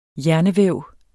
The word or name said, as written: hjernevæv